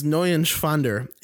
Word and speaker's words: Neuschwander: You didn't pronounce it right. Anthony Neuschwander. – It's N-E-U-E-N. It's Neuschwander. Anthony Neuschwander. He's Swiss, so, you know, that's a Germanic last name.